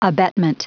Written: abetment

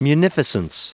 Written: munificence

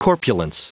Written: corpulence

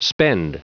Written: spend

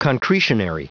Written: concretionary

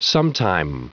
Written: sometime

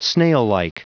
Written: snaillike

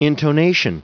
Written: intonation

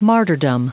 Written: martyrdom

